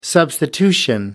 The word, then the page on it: substitution: American English